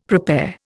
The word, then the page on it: prepare: Indian